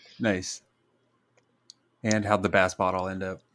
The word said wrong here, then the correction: boss battle: Bass bottle, or if you're saying it correctly, boss battle.